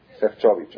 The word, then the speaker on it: Sefcovic: La que escribe se llama Sara Sefcovic, Sefcovic. ¿Cómo? Shevchovich, ok, así se escribe, ¿Qué, qué, de qué religión creen ustedes que es? – Ok.